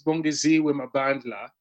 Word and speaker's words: Bongeziwe Mabandla: Yeah, the name is Bongeziwe Mabandla.